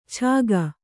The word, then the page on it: ಛಾಗ: Kannada